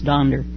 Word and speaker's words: Donder: Only in the German pronunciation it's Donder. D-O-N-D-E-R.